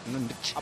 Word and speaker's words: Enrich: Di Enrich, que si no lo estamos pronunciando mal. Es Enrich. – Vale, Enrich.